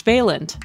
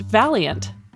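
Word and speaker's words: Vaillant: She refers to him as George Valiant, but his last name is actually pronounced Valiant, a suitable patronym given his bold work investigating how individuals evolve over the course of their lives.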